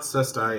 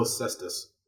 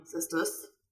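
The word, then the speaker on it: caestus: It's not Cestai. It's still Cestus. – Oh, it's Cestus pronounced well? Slightly differently. – Cestus. – Spelling. It's from the Latin version, it's C-A-E-S-T-U-S, with the plural, it's a line over the U.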